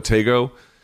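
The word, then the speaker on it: Tetteh: Over on DAZN, Ryan Garceau demolished Emmanuel Tego, I think is how they pronounce it. Not Tego, but Tego.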